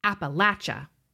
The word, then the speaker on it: Appalachia: Appalachia, though deep enough that we learned the local pronunciation, Appalachia.